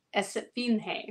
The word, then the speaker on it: Esfinge: If your wrestling name means Sphinx, do wear an awesome golden jackal mask like the CMLL wrestler Esfinge. Did I say that right? – Mm-hmm. – Yes.